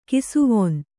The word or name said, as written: ಕಿಸುವಣ್ಣ